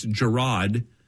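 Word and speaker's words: Jerrod: Yeah, how does he say his name? It's not Jared. It's Gerard Carmichael.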